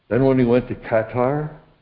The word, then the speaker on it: Qatar: Just this past week, Trump went out to Saudi Arabia, Qatar, apparently that's how they pronounce it, and United Arab Emirates.